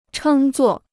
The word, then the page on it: 称作: chēng zuò